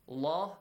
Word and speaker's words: الله: الله الله is pronounced heavy sound, heavily.